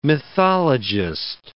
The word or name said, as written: mythologist